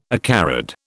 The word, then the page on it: acarid: British English